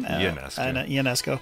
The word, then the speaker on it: Ionescu: Ionescu.